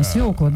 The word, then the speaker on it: свекла: Да не чаще всего, так правильно, свекла, да, действительно свекла.